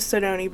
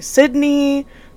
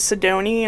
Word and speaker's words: Sydoney: They either called me Sydney, Sedona, and the list goes on and on. My name is Sydoney, and that is spelled S Y D O N E Y.